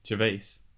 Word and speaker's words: Gervais: Do you want to pronounce his name right, please? – Oh my life. – Ricky Gervais. – Gervais? – Yes.